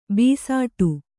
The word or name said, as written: ಭಿಸ್ಸಟೆ